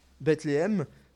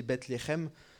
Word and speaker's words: Bethléem: De plus, Bethléem, en hébreu, ça signifie « la maison du pain ». Bon, c'est pas très bien prononcé, il faudrait prononcer Bethléem, mais ça veut dire la maison du pain.